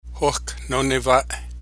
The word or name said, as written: Hohkahnoneva'e